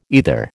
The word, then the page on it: either: American English